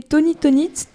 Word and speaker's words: Tony Tonit: Et donc voilà, c'était Tony Tonit, et donc pas Tong Toit comme je l'ai prononcé.